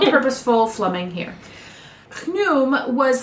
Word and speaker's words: Khnum: His name was dedicated to the god Khnum. It's spelled K-H-N-U-M, but I listened to a lot of recordings, and I'm pretty sure it's Khnum.